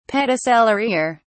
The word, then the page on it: pedicellariae: British English